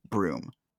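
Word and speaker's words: Bruttenholm: which is actually spelled out like Brutenholm, but it's pronounced Broom.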